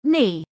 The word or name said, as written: Knee